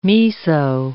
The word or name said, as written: miso